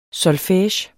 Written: solfege

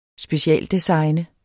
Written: specialdesigne

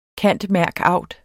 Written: cand.merc.aud.

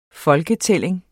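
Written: FOLKETÆLLING

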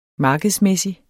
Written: markedsmæssig

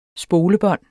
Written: spolebånd

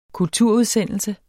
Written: kulturudsendelse

